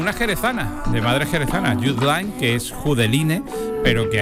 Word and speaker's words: Judeline: Y entonces se escribe Judeline, pero es Jude Lyne, que es el nombre artístico, y que es la primera andaluza que ha estado en el top mundial de reproducciones.